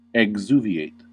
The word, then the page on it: exuviate: American English